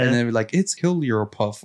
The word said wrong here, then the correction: Jigglypuff: Did I say it right? – No. – How do you say it? – Jigglypuff? – Jigglypuff. – It's a Hillbillypuff. It like, Jigglypuff. Girl. And I was like, oh, damn, well, I got that wrong.